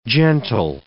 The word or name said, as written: gentle